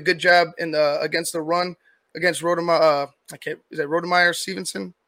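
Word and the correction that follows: Rhamondre: Is that how you pronounce the first name for the Patriots? Stevenson, the running back. – Oh, Ramondre. Anyway, Ramondre. Yeah. Ramondre.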